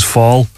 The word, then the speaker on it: Fall: And really, that would have come about when I worked with someone who kind of deliberately mispronounced my surname, which is Fall. So he used to call me Flaw, just to kind of wind me up.